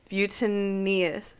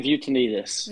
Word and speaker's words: Vutianitis: I'm here with, I'm going to try to concentrate, Coach Brian Vutianitis. No, I said it wrong. Go ahead, say it for me. – Coach Brian Vutianitis.